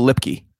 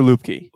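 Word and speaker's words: Luepke: We're gonna get called out for mispronouncing his name. I'm just waiting for it. – I'll go Lipke. – No, the announcers were saying Lupke. Hunter Lupke. – Lupke. – I'm pretty sure. Yeah, Lupke. But he gave me like this Peyton Hillis type vibe because Peyton Hillis was a fullback.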